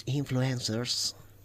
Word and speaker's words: influencers: Este es el programa que Onda Cero dedica a las nuevas tecnologías, a los youtubers, a los influencers.